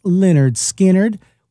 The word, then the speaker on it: Lynyrd Skynyrd: pronounced Lynyrd Skynyrd, which was released on this day back in 1973.